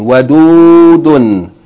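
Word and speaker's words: Wadudun (وَدُودٌ): Three syllables, short vowel, long vowel, tanween. Wadudun.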